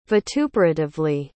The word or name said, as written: vituperatively